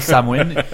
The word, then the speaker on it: Samhain: Là, ça s'écrit Samhain, ça se prononce Samhain.